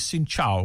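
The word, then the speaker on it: Xin chào: And the other word you need to learn is Xin Chao, which is hello. – Xin Chao. How do you say goodbye? – Xin Lo is excuse me, and come on, C-U-M-O-N, is thank you.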